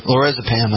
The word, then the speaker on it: lorazepam: it's Olazepram or something like that. Lorazepam, I mean.